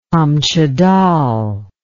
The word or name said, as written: kamchadal